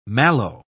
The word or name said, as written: mallow